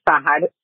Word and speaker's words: Sahad: My name is Sahad, um, phonetically pronounced S A H A D.